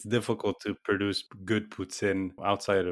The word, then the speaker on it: poutine: It's pronounced poutine.